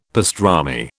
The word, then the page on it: pastrami: British English